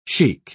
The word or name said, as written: sheik